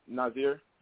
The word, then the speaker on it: Naziir: Well, it's Naziir.